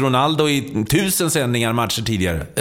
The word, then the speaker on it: Ronaldo: Ja, men alltså, vi har en kollega som fick nys på att den gamle Ronaldo på portugisiska ska uttalas Ronaldo.